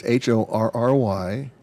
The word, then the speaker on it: Horry: Again, remember the H is silent. – But it's Horry.